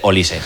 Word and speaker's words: Olise: Él ya pues bueno, ha resuelto todas las dudas con esos cartelones que salen con su nombre y pronunciando para el narradores y demás. Información útil, vale. Dice Olise, vale. Normal.